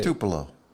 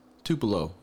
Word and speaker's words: Tupelo: Did, did we just rename it? Uh, how do you say it? How do you say it? Tupelo. – Tupelo. Yeah. I don't know why I keep saying Tulupa.